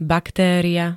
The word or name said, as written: baktéria